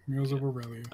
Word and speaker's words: Wheels of Aurelia: We got Just Cause 4 and Wheels of Aurelia. Is that how you would pronounce it? – Yeah, Wheels of Aurelia.